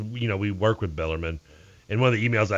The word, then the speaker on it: Bellarmine: It's Bellarmine. How's there any question? – Because it reads like everybody says Bellarmine. Like everybody last year, M-I-N-E, they read it, they say Bellarmine.